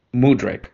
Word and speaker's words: Mudryk: I don't know how the fuck to say his name. I think it's Mudryk. – Mudryk.